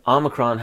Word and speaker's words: Omicron: So Omega has the long O sound. Omicron has the short O sound.